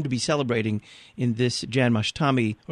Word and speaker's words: Janmashtami: the Krishna Janmashtami, the way we would pronounce it, Janmashtami, is basically a remembrance, so to speak.